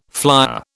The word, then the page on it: flyer: British English